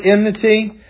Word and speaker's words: enmity: That word enmity, enmity is actually how you say it. I usually get the M and the N mixed up.